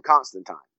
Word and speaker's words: Constantine: And I thought it was great he called him Constantine, because if you read the Hellblazer comic books, that is actually the correct pronunciation of John's last name, because you got to remember, it's British. And but Americans always call him Constantine, because I think we relate it to, you know, Constantinople and that.